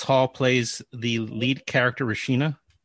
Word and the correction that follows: Renisha: I think I said that wrong. – Renisha. – Renisha.